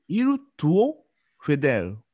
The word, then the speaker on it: il tuo fedele: il tuo fedele。 – はい、であの L を結構前の方にしてくださいあの英語発音だと L が後ろになっちゃうのでエル、 L, L も結構もう下がつくエルは前。